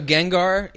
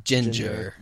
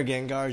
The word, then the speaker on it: Gengar: So Gengar, you know, the ghost. – Yeah. – Chuck pronounces it Ginger.